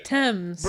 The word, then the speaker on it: Thames: Thames.